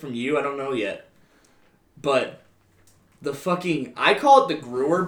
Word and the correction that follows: Gewehr: but I guess I pronounced it wrong all this time. I guess it's the Gewehr. – The Gewehr, yeah.